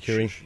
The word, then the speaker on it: Shaqiri: Shaqiri.